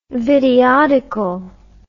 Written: videodical

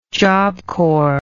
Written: job corps

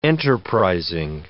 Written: enterprising

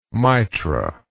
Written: mitra

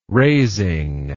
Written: raising

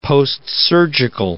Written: postsurgical

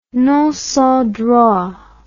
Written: non sans droit

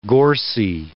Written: gorsy

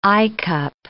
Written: eyecup

